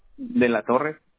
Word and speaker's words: De la Torre: De la Torre. – Okay, can you spell that? – D-E-L.